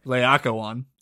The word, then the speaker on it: Laocoon: That's how you say it? Okay. I wasn't sure. – Laocoon. Yeah. – In my head, it was like Laocoon, and I was like, that's not it. – That doesn't sound Roman. – I don't know any better, but I know that it's wrong. – I could be butchering it myself. You know me, I'm not the greatest at pronouncing things, but it's something like Laocoon.